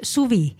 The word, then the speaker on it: Suve: oktoobril ilmub täitsa uus lühialbum pealkirjaga Suve . – jah , mida tegelikult võib isegi öelda , hääldada siis Suvi , sest et ta ongi nagu inglise ja eesti keeles ja ma võin nii palju öelda ,